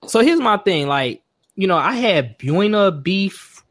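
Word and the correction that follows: Buona: Did I say it? How, how you say it? – I think it's pronounced Bona. Bona.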